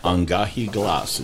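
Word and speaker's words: An Gèadh Glas: and Angahi Glas is how you say it in Gaelic.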